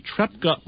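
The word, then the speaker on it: Trepča: The single most important piece of economic real estate in Yugoslavia are the Trepka mines.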